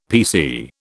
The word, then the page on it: PC: British English